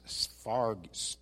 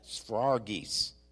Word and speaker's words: sphragis: The Greek word for seal is sphragis. It's a very difficult word to explain or pronounce. Sphragis. Yeah, sphragis. That's it. I pulled it up on the internet and had it pronounced. Sphragis.